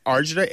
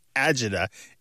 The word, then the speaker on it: agita: Usually these days it is spelled A-G-I-T-A, but you might see it as arjita, A-R-G-A-D-A, or Agida, A-G-I-D-A.